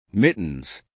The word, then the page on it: mittens: American English